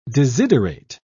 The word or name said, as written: desiderate